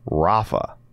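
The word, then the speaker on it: Rapha: And we also have a new collection with Rafa. Did I say that right? – You did, actually.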